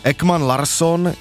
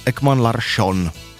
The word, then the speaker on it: Ekman Larsson: A sice, že čeští komentátoři začali vyslovovat švédská jména, tak jak je vyslovují Švédi. To znamená, že Ekman Larsson je najednou Ekman Larsson. Persson je Persson.